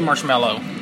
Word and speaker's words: marshmallow: I say marshmallow. – I say mellow, like, hey, mellow out, dude.